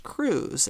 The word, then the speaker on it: Cruz: And I said, no. I said, that's pronounced Cruz. I said, like Terry Crews from America's Got Talent.